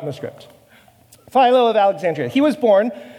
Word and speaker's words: Philo: As Junior was reminding me, it's actually pronounced Philo in the Greek, but nonetheless, everybody says Philo because we are anglicized and domineering colonizers, apparently.